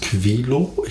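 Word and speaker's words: Quilow: Wir waren im Wasserschloss Quilo. Ich hoffe, ich habe das richtig ausgesprochen. Wasserschloss Quilo, wirklich mit Q. Mit Q am Anfang. – Und W am Ende.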